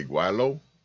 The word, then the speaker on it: gweilo: Yo, and I mean, like that because now I can sit there and say gweilo.